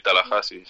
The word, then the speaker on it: Tallahassee: Por cierto, no sé si lo he dicho bien. ¿Cómo lo decís ahí, Tallahassee? – Sí, Tallahassee. Sí, está bien.